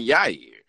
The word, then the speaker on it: Yair: And it has someone in it whose name rhymes with year, in Yair.